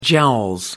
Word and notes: jowls: British English

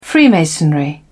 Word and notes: freemasonry: British English

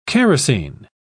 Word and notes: characin: British English

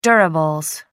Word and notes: durables: American English

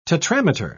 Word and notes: tetrameter: British English

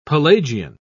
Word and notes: pelagian: British English

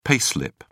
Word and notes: pay slip: British English

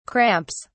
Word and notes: cramps: British English

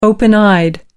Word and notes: open eyed: American English

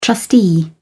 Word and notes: trustee: British English

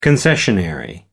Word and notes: concessionary: American English

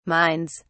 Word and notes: minds: British English

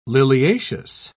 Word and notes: liliaceous: British English

